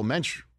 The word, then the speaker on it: Mensch: He's a diehard, well, Mensch rhymes with Dench.